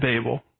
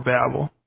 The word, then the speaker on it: Babel: So Satan's goal is to subvert the divine ordering of nations at Babel, or Babel. Just depends on which syllable gets the emphasis, as one of my professors like to say.